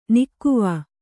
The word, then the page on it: ನಿಕಾವು: nikāvu